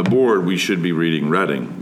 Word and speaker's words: Reading: Reading appears on the Monopoly board, though it is often mispronounced as the Reading Railroad, but we are the Reading Railroad. So now when you play Monopoly, I hope you will pronounce the railroad correctly, please.